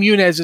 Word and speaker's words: Yunez: But there's a city in the in the thing called Yunez, and Yunez, the name Yunez is actually a phonetic pronunciation of U N E S, which is U- U- United Nations Earthship.